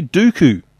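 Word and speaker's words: Duqu: Now, am I pronouncing this correctly? Dooku. To spread. – Yeah, I think that's pretty close. – Is that close. – How you pronounce it? – Yeah.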